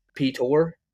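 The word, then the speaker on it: Piotr: like you would in America, because he's Polish. Peter Lysik is Pitor. Pitor. Lysik is Lysek.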